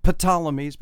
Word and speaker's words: Ptolemies: It begins, the word begins with a PT, so this is kind of a silent P. The Ptolemies, but I'm going to make the P silent again.